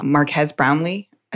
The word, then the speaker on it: Marques Brownlee: But then I read the reviews and saw Marques Brownlee. I think mm that's how you yeah, say his name.